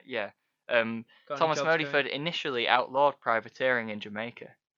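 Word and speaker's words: Modyford: A Sir Thomas Modiford. Sorry, not Moodyford.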